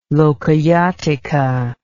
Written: Lokayatika

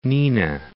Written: Neenah

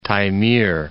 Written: Taymyr Peninsula

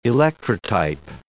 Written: electrotype

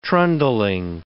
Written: trundling

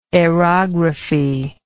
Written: aerography

